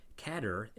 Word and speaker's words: cadre: The final A sound may have been introduced by way of another French borrowing, cachet. That's C-A-C-H-E-T. Remember that the French often don't pronounce the final consonants in words. The parler of Parlez-vous Français ends in a Z. Other French borrowings that end in an A sound, like cliché and protégé and résumé, may have also played a role in lending cadre an A ending.